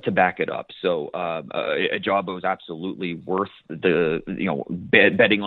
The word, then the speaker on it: Ojabo: David, Ojabo, he's... – Oh, it's a hard J, my bad.